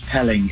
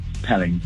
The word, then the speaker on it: Pelling: It's Pelling. It's, Pelling. As in spelling, but without the S.